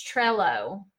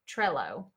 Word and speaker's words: Trello: she says it's trello trello okay i,